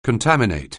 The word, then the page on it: contaminate: anglais